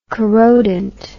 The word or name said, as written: corrodent